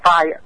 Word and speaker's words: Phi: Phi ở đây tức là PHI đó quý vị, PHI ngắn thì em đọc là phai, tên một cái chữ cái của tờ bảng mẫu tự Hy Lạp là tích quê.